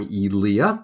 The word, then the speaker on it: Ilya: I'm Ilya, but that's cool. – Ilya. Okay, so I missed the accent on the second syllable.